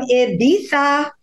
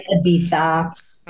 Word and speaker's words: Ibiza: Guys, I'm coming to you live from Ibiza. That's how the locals say it. That's right, Ibiza.